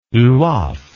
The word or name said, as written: lwoff